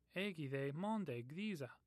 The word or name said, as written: monde